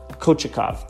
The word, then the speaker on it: Kochetkov: So I think we could very well see him in net in the quarterfinals, and I think we could see him, Kochetkov, that's his name, that's how you pronounce it.